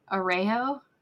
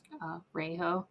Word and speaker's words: Araujo: So it's pronounced Gwen Arujo. Arujo.